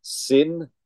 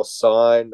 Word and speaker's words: Syn: Um, uh, I think it's pronounced sin, S Y N. – Yeah, uh, sin would be yeah. Sin or sign, I don't fucking know. Sin. Let's call it sin.